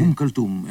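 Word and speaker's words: Umm Kulthum: Esse músico que eu não vou usar pronunciar, que tu disseste anteriormente, é Umm Kulsum, é uma cantora egípcia. – Ah, Umm Kulsum, sim.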